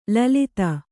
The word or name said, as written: ಲಲಿತ